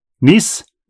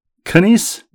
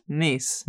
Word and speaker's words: Kneese: Happy birthday, Carrie Smith, even, and Marty Smith Bird from the oldest of the five Smith ladies, Nancy Smith, the last name K-N-E-E-S-E. You pronounce that, Rebecca. Niece? – Yes. – Canice? – Niece.